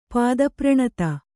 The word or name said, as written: ಪಾದಪ್ರಣತ